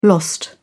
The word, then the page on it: lost: British English